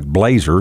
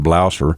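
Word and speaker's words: Blaser: And the spelling of that, and if we was to pronounce it in English, it looks like Blazer. Uh-huh. But because it's a German gun, it's pronounced Blosser.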